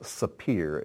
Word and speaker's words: sapir: It's pronounced sapir in Hebrew, but this, whoops, I don't know what happened there, but I'll be more careful.